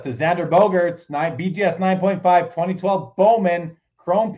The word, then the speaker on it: Bogaerts: Bogaerts.